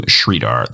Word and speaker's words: Sridhar: My full name is Andrew Sridhar. That is S as in Sam, R-I, D as in David, H-A-R.